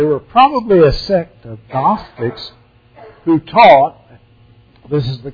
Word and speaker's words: Gnostic: Adam Clark's commentary, he said, they were probably a sect of Gnostics. The word Gnostic starts with a silent G. Like the word knowledge starts with a silent K.